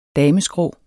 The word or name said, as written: dameskrå